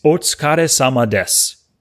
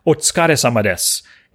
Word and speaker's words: otsukare sama desu: but it's otsukare sama desu. Once again, otsukare sama desu. And that's spelled, it's three different words, otsukare, O-T-S-U-K-A-R-E, sama, S-A-M-A, Desu, D-S-U.